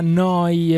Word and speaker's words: Neu: Oh, Noi Radio Neu Radio si scrive Neu. Si pronuncia Noi e vuol dire nuova, nuova emittente urbana da Bologna.